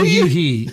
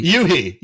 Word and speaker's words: u-he: Is it, is it u-he? Oh, u-he. u-he. u-he. – u-he. Okay, u-he. I would say I say u-he,